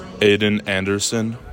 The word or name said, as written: AIDAN ANDERSON